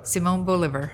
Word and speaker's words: Simón Bolívar: Simón Bolívar.